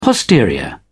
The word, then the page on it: posterior: British English